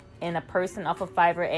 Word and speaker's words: Fiverr: It's called Fiverr. Yes, I said it right. Fiverr.